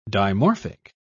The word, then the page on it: dimorphic: British English